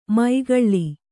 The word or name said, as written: ಮೈಗಳ್ಳಿ